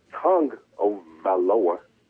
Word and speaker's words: Tagovailoa: I thought it was Tago, but I found it Tagovailoa. That's what I found out. Tagovailoa.